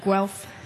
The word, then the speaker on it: Guelph: I'll be with the Tanyas again tomorrow night in Guelph, I believe is how you say it. Is that correct? Guelph? – Yes. – I'm looking forward to it.